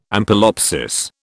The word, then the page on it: ampelopsis: British English